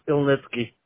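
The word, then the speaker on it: Ilnitskiy: My last name is Ilnitsky.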